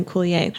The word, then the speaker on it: Coulier: Coulier. How do you spell that? C-O-U-L-I-E-R.